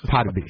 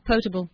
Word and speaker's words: potable: Potabi. It wasn't portable. It was Potabi. – What was it? Was it portable? – Wannabe? – Potable.